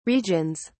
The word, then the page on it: regions: British English